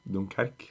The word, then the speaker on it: Dunkerque: Og så er det franske laget som ikke klarer å uttale Donker... – Dunkerque. – Dunkerque.